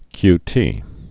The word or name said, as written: qt